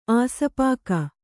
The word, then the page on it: ಆಸ್ಪಾಕ: āspāka